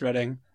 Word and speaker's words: Reading: Yeah, it's spelled like Reading, but it's pronounced Reading.